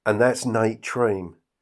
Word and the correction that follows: Treme: Hold up. No, it isn't. It's Nate Treme, you plonker.